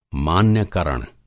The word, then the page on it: मान्यकरण: Hindi